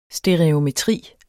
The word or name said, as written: stereometri